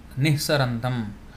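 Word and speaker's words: niḥsarantam: Nihsant, nihsarantam. So it is like that, as I pronounce this now, nihsarantam.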